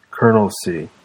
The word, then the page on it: colonelcy: American English